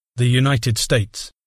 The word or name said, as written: the United States